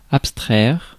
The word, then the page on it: abstraire: France; /ap.stʁɛʁ/